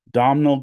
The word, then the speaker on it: Domhnall: One is Dom, let me say his name right, Domhnall Gleeson.